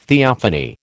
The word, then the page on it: theophany: American English